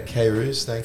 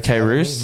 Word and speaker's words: Kairouz: Is that how you say it? – Yeah, Kairouz. Kairouz.